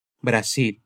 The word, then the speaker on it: Brasil: E a própria palavra Brasil com uma pronúncia diferente para o S e para o L. Bora repetir? Outro. Brasil.